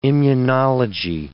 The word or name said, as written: immunology